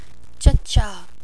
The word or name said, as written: Chucha